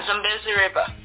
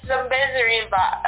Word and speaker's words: Zambezi: Did I pronounce that correctly? – Yeah, Zambezi River. – The Zambezi. Now that sounds like a magical place, man. – Now the pronunciation is a little bit weird, but yeah, it's Zambezi River.